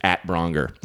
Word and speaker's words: Braunger: And, my Twitter is @Braunger. Okay. You get it? It's just the at symbol and it rhymes.